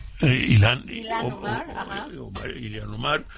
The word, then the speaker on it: Ilhan Omar: Está Alexandra Ocasio-Cortez, ¿cómo se pronuncia? ¿Ilhan Omar? – Ilhan Omar.